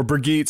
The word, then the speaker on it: Brigitte: Um, Jeff Kaplan pronounces it uh, Brigitte or Brigitte. Sorry, it's not a soft yeah, G; it's a hard G.